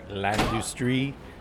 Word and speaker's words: L'Industrie: A place called L'Industrie, or I guess if it's French, if you're pronouncing it in French, L'Industrie.